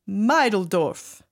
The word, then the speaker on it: Meideldorf: Just remember it's not pronounced like middle. It has a longer I sound to it. That's right, Meideldorf.